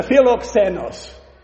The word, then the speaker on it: philoxenos: This is the philoxenos. Love for strangers, if you want a literal translation of the word. Philoxenos, we pronounce the x.